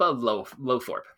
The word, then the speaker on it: Lowthorpe: And I trust I got close on that pronunciation. Uh, I'm just going to try and say it with more confidence. Philippa Low- Lowthorpe. There we go. That that sounded right.